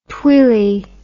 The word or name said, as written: twillie